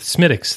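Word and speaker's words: Smithwick's: This week, I'm finishing out what's left in the fridge, and so I am dealing with Smittix this evening. Premium Irish Ale. – Ooh. Thank you for pronouncing it in the correct fashion.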